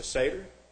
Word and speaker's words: satyr: You know, his top half is a man, bottom half is a goat, the satyr. I'm pronouncing that right. Yeah, the satyr.